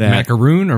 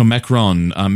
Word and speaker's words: macaron: I went on that and I had a Snickers caramel peanut milk chocolate Mickey macaron. So it's a macaron, one of those French cookies. – Is it macaroon or macaron? – No, macaron.